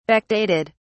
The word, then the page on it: backdated: British English